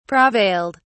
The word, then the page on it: prevailed: British English